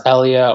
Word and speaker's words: Elia: E-L-I-A, it pronounced Elia or Elia.